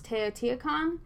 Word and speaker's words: Teotihuacan: In the next paragraph, we talk about a city pronounced Teotihuacan, and it's located in the central valley of Mexico.